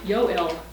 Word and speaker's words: Yoel: Actually, the way you would pronounce Joel in Hebrew is Yoel.